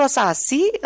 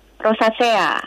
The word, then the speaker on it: rosacea: Itu bacanya betul rosasi uh, atau rosasea? Rosacea. Rosacea, oke. Okay.